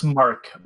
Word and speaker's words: Housemarque: It's Housemarque. Marquee would have two E's.